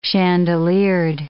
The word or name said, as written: chandeliered